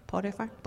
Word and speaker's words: Potiphar: Is that how you say it? Potiphar? Potiphar? – Potiphar.